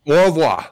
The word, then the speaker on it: au revoir: Au revoir? – Yes, au revoir. – Yep, au revoir.